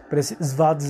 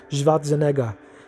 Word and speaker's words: Schwarzenegger: Primero tenemos a los depredadores que aparecieron por primera vez con Arnold Schwarzenegger Svat como me decía una amiga, que es la, pronunciación correcta. Schwarzenegger.